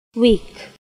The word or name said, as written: Weak